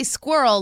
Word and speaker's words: squirrel: I say squirrel like it rhymes with girl.